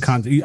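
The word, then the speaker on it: Conti: Well, I'm going to argue with you on the pronunciation of his name. Because I'm saying Bill Conte, you're saying Conte. – Probably Conte. I think you're right.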